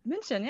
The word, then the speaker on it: München: I don't know how to say it in German. München? – München, yeah, right.